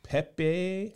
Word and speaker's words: Pepi: Pepe. – Spell it. – P-E-P-I. – P-E-P-I. – Okay. Last name, S-O-N.